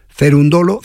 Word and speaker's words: Cerúndolo: Este juego, aunque lo haya ganado Cerúndolo, que lleva acento en la U y tal,